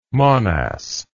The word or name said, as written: monas